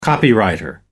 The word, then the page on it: copywriter: American English